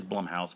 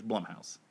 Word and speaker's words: Blumhouse: It is Blumhouse, but I said Blumhouse there. It's Blumhouse.